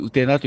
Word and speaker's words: 台: 大という字を書いて、うてなと読むんですけど、はい、ちょっとした高台がたくさんあったと言われて、そのから仙台というのがついたとかですね。